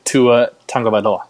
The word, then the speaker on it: Tua Tagovailoa: Buy or sell, Joe, Kyler Murray deserves the Heisman over Tua Tagovailoa.